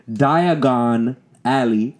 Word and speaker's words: Diagon Alley: He knows Diagon Alley. – Yeah. – It's spelled diagonally. Diagon Alley.